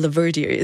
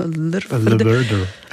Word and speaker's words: LVRDR: Werde aber gleich ausgesprochen, Laverdier. Es ist eigentlich einfach, ja, die Vokal weglassen. Yeah sieht cool aus, aber wenn das so liest, ist es so... Leverder.